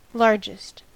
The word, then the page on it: largest: American English